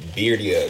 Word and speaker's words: birria: That they're actually called birria tacos. – Is that how you say it? – Yeah.